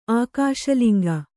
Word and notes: ಆಕಾಶಲಿಂಗ: Kannada

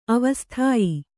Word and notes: ಆವಸಥ್ಯ: Kannada

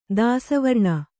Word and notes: ದಾಸವರ್ಣ: kannada

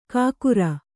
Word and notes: ಕಾಕುರ: kākura

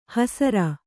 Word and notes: ಹಸರ: hasara

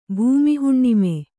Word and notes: ಭೂಮಿಹುಣ್ಣಿಮೆ: Kannada

